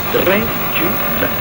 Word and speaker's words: Dracula: The sound is so intriguing. Three syllables. Dracula.